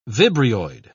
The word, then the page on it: vibrioid: British English